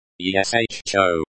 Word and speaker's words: еще: Еще. This word means, still, or, yet, and is used to indicate that something is not finished, or has not happened. Pronunciation. ESHO. Не.